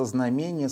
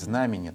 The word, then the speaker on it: знамение: И вот тогда, вот после этого, явится знамение Сына Человеческого. Или правильно говорить, знамение, да, наверное.